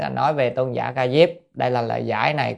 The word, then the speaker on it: Ca Diếp: À, Tôn giả Ca Diếp, chữ Diếp hay Diệp vậy, nhưng mà chúng ta hay đọc là Ca Diếp nhé, hoặc đọc Diệp.